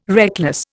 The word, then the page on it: reckless: Indian